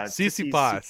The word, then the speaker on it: Tsitsipas: Tsitsipas.